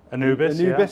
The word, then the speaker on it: Anubis: Since we last met, you've left Pinnacle and set up your own business, Anubis, is that the correct pronunciation? – Anubis, yeah.